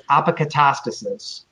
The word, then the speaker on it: apokatastasis: It's Greek. It's apokatastasis.